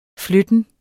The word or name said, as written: flytten